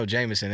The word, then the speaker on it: JMSN: If you don't know Jameson, it's spelled J M S N.